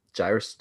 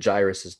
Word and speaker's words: Jairus: Jairus' daughter, and I've always heard it Jairus's daughter. You said, but how do you pronounce that? I think you said Jairus. – You know, it's the Southern version.